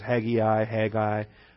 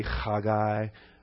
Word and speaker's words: Haggai: Yeah, and I've heard it pronounced in English different ways: Haggai. The Hebrew would be Chagai. The Hebrew Chag is a festival or a party, and so Haggai is a party animal.